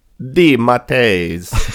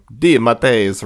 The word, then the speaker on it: DeMatteis: And we've never gotten a DeMatteis clarification. – DeMatteis. – It's That's Italian Elon Musk pronunciation. Uh-huh. DeMatteis, yeah. – So yeah, when this JM DeMatteis run is excellent, and definitely go read it.